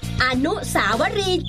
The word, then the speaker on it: อนุสาวรีย์: อ่านว่าอนุสาวรีย์จ้ะไม่ใช่อนุสาวรีย์เพื่อนหลายคนเขามักออกเสียงผิดแบบก้อยนี่แหละ